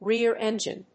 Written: rear engine

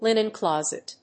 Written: linen closet

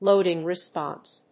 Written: loading response

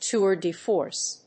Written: tour de force

